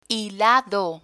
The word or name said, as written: hilado